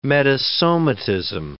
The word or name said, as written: metasomatism